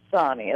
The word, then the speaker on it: Sonni: My name is pronounced Sonni, S-O-N-N-I.